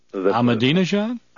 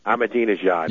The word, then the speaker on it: Ahmadinejad: Ahmadinejad? – Ahmadinejad.